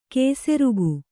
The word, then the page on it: ಕೇಸೆರುಗು: kēserugu